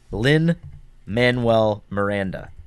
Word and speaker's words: Lin-Manuel Miranda: Lin-Manuel Miranda? – Lin-Manuel Miranda.